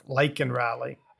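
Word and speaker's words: Laken Riley: And he did say her name, but he actually mispronounced it and said Lincoln Riley instead of Lakin Riley.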